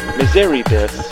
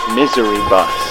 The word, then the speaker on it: Miseribus: It looked like it said, Miseribus. You ask if it's a family name. He tells you it's pronounced Misery Bus.